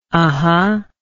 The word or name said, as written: uhhuh